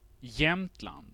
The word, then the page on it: Jämtland: svenska